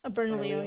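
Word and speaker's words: Bernalillo: Burn Leo. I'm assuming that's how it's pronounced. – Burn Leo, yes.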